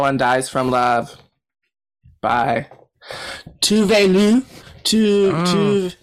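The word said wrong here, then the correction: Tove Lo: That's not how you say it. – You mean Tavlo.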